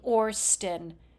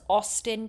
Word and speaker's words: Austin: nobody pronounce Austin, Texas as Orston.